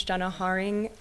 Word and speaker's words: Jenna Haring: Um, my name is Jenna Haring, J E N N A H A R I N G.